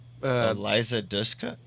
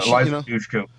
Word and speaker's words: Eliza Dushku: Eliza Dushku? – Yeah. – Eliza Dushku.